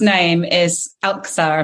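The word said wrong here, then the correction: Älskar: Am I pronouncing that right? – Elskar. – Almost. Elskar. Yeah.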